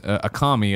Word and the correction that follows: Akamai: It's actually Akamai.